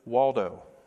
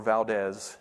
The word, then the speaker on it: Waldo: They were the disciples and the product of a man named Peter Waldo, or Valdez, depending on how you pronounce his last name.